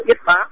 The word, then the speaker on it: Yết Bá: Ở đây thì riêng tâm thì có tên nữa là đó là Thương Tinh, Ư Bá Chi Tinh và Thiên Vương. Chữ Ư Bá này đọc là Yết Bá.